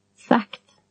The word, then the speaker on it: sagt: G is pronounced K. – Högt. – High. – Sagt.